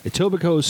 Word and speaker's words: Etobicoke: LCBO is aware of the shop theft incident at one of our Etobicoke. The K is silent. Etobicoke's store locations on Friday and are grateful that there were no reported injuries, according to the LCBO press office.